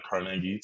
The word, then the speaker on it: Carnegie: So, yeah, I grew up in a single mother household in Pittsburgh, Pennsylvania, Carnegie to be exact, the correct way to say it.